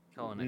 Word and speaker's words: Kelenic: Like Kelenic, is that how it's pronounced, or is it Kelenic, knowing me? – Kelenic. Nah, yeah, Kelenic.